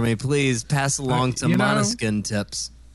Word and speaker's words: Måneskin: Måneskin? – Yeah.